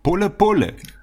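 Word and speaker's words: pole pole: und das heißt auf Swahilisch pole, pole.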